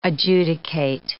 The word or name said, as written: adjudicate